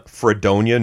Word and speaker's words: Fredonia: Are you from Fredonia, New York, and am I pronouncing that right? – Yes and yes.